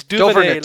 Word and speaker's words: Duvernay: It's Duvernay. Like your Gambit, Duvernay. You know, from the X-Men Gambit.